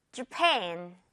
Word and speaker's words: Japan: Japan 앞부분 이 J, A 부분은 좀 가볍게 한다는 거 포인트죠. 듣고 따라해주세요. Japan.